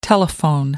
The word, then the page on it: telephone: American English